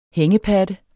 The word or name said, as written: hængepatte